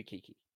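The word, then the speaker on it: Keke: Kiki Palmer. That's her name. Kiki Palmer. – Okay, yeah, you can't butcher that one. – That's right, it's K-E-K-E, so it's got to be Kiki.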